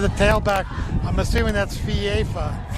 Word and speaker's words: FIFA: It is pronounced FIFA. Two syllables, FIFA.